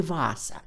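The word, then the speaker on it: Vaasa: Сейчас его название произносится по-фински «Ваза», а другой Нейшлотский переулок так и сохранил свое название.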